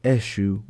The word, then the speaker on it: Eshu: Before we continue on with the rest of this recording, I would like to note that there are several points throughout that I refer to Eshu as Ishu. That is a mispronunciation on my own part during the initial recording of this episode, and I would like to apologize. The proper pronunciation is Eshu Bumpus.